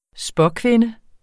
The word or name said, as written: spåkvinde